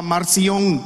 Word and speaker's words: Marción: Pero Policarpo tuvo un encuentro con un señor que se llamaba Marción, M-A-R-C-I-O-N, Marción, no se equivoque con otra palabra, ¿verdad? Marción, y una creo que lleva acento en la O.